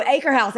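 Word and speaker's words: Akershus: Visually or phonetically, I call it, it looks like Acacius. But I have heard cast members call it Acre House. – Oh, Acre House. It is exactly how they say it. – That's how I've heard it's Acre House. But if you look at it on paper, if you wanted to Google what we're talking about, it's Acacius.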